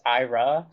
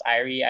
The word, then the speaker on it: Irie: Yeah, I know that there's a band from the East Coast called Irie, that's like, it looks like it would be pronounced Ira. Mm-hmm. But it's I guess pronounced Irie.